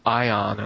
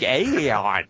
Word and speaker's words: Aion: It's spelled A-I-O-N. – Oh, yeah, yeah, yeah. I would have expected a more tortured rendition of the vowels, like Aeon, like something like that.